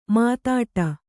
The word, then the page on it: ಮುದುಡು: Kannada